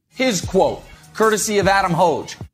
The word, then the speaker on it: Hoge: Like hose, but with a hoge. – Which I think the lineman out of BYU this year that got drafted, he spells it H-O-G-E, and I'm pretty sure that's how he pronounces hoge.